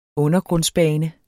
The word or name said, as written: undergrundsbane